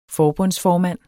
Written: forbundsformand

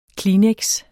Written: kleenex®

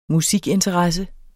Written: musikinteresse

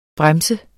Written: Bremsen

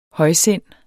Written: højsindet

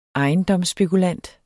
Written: ejendomsspekulant

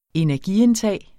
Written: energiindtag

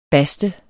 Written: baste

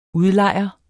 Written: UDLEJERE

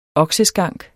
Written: okseskank

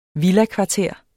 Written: villakvarter

